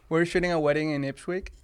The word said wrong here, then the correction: Ipswich: Did I say it right? – No. – Okay. – Ipswich. – Ipswich. – Yeah. Okay. – Like a sandwich.